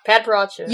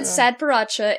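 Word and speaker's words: padparadscha: It's a P. Yeah. Padparacha. – You said Sadparacha, and now I will never say it right again.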